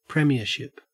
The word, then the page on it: premiership: Australian English